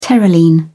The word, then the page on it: terylene: British English